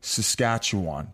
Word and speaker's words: Saskatchewan: You guys were born in Saskatoon, Saskatchewan.